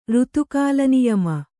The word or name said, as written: ಋತುಕಾಲನಿಯಮ